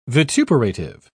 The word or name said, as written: vituperative